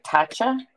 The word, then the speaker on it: Tatcha: Tatcha, T-A-T-C-H-A.